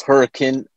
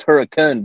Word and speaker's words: Hurricane: I believe it's Hurricane, but it's spelled Hurricane. – Yeah. – Pronounced Hurricane because we're Utah.